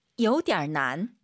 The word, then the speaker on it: 有点难: 点难 이게 포인트예요. 요点 올라가면 안 돼요. 요点难 얼화를 집어넣어서 有点难.